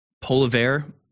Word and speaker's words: Poilievre: and calling Pierre Poilievre, I don't know how to pronounce his last name, Pierre P, is that what we should call him? Poilievre, like polar bear?